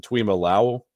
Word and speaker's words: Tuimoloau: I mean, you got players like the Ohio State, both of those guys, Jack Sawyer, JT to, how do you pronounce it? Tuimoloau. – Sure. Yeah, nailed it.